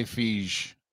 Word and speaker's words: Fieg: F-I-E-G. Feige? – I said Feig.